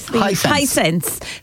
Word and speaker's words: Hisense: Hisense 32-inch smart AI TV.